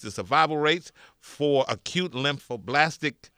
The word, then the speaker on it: lymphoblastic: Marley has acute lymphoblastic leukemia.